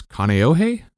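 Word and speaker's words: Kaneohe (Kāneʻohe): I think it's Kaneohe? – Yeah.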